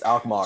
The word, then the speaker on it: Alkmaar: Alkmaar, I believe, the way it's pronounced. – It's Alkmaar. – So Alkmaar, sorry.